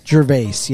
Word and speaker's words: Gervais: Gervais, yes, sorry.